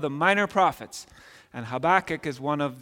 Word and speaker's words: Habakkuk: I am told that if we were ancient Israelites, that is how we would say that. Habakkuk. I won't say it like that anymore. I'll stick with my pronunciation, Habakkuk, and you can call it what you like.